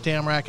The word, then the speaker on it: Damrak: It's a weird word to pronounce for an American, huh? – Damrak? – Damrak. – Damrak. Damrak. Damrak. It's not bad. – Damrak. – No, there's no Damrak, mate. – It's two syllables.